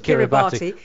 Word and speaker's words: Kiribati: Here is Stephen Harper, who says Christmas Island in the Pacific Ocean is part of Kiribati. – Yes, we pronounce it Kiribati. That's just its nickname. Just, you know, you stick a Y on the end of names, don't you? – Kiribati. Kiribati it is.